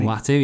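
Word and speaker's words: Uatu: Watu? Am I saying that right? – Watu, yeah, the Watcher.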